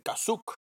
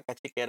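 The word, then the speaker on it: Kashyyyk: Ah, claro, verdad regresando a los imperiales en la televisión de la familia de Chewbacca, cuando dicen cachic, es como una pronunciación ahí de eh, kazuk. De nuevo, vamos a cambiar la. Eh, me imagino que en aquel momento lo más cercano a Cachique era esa pronunciación y hoy día pues ya está cambiada.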